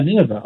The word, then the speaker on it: Nineveh: Can you talk to us about Nineveh, if I'm pronouncing that correctly? – Yeah, Nineveh.